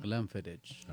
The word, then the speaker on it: Glenfiddich: Glenfiddich. – Glenfiddich. Are you pronouncing that right? – Glenfiddich.